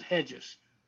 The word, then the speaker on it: Regis: Hedges is making huge, it looks like Regis. It's pronounced Hedges, R E G I S.